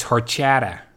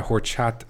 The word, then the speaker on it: horchata: It's horchata, but pronouncing the H. – It's horchata. It's not horchata. Horchata? No H. I, I want, mean, there is an H, but you don't.